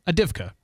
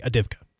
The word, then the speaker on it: Avdiivka: That's how it's spelled, but yeah, Avdiivka. Yeah. I would not say the first V, Avdiivka.